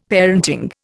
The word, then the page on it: parenting: Indian